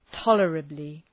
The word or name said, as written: tolerably